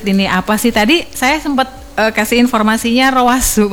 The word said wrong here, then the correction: Ruazet: padahal bukan itu ya bacanya, Ruazet ya Bu Fatma ya. – Iya, Ruazet.